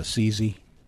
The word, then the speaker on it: Assisi: Yeah, that's how you pronounce that. – It is, and do you know that for sure? Just like, or you just? – I had a bishop tell me that when I was in Assisi.